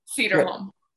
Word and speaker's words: Sederholm: Sederholm.